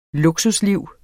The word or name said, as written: luksusliv